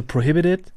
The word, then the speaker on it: Prohibited: Also so richtig Englisch wäre eigentlich so prohibited. – Prohibited. – So mit so einem D quasi schon statt des Ts.